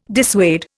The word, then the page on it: dissuade: Indian